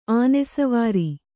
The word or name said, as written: ಅನುಸ್ವಾರ